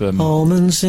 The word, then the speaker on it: almond: almonds in my teeth, almond. – I know. You call them almonds? – I struggle to do that silent L that people do.